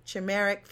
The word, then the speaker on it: chimeric: In some patients, this technique temporarily created a chimeric fusion, which is a fusion protein. And I think I said it wrong because Landon just smiled. – You said it wrong. Chimeric. – Chimeric immune system.